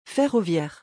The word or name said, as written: ferroviaire